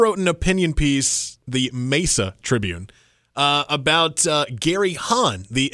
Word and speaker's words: Mesa: About just this one little pronunciation, the Mesa. – Mesa, thank you.